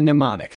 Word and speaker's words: mnemonic: Memory researchers call this act of mental gymnastics a mnemonic. No need to remember that word, though once I tell you that it is one of the precious few English words that begin with a silent M, you probably will remember it.